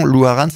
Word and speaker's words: Loharano: Alors, Louarane, il faut le dire, parce que vous le prononcez comme il faut. Mais nous, en français, on l'écrit un petit peu autrement que cette prononciation. Louarane, ça s'écrit L-O-H-A-R-A-N-O.